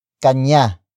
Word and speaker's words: kanya: Kanya ba ang libro? Kanya ba ang libro? Kanya ba ang libro? It means, is the book his or hers? Word for word it's kanya, ba, ang, libro.